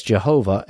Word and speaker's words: Jehovah: That Tetragrammaton, by the way, translates into a KJV as Jehovah, and that's the oldest known pronunciation, albeit with a Y instead of a J, because in Hebrew you don't have a J sound.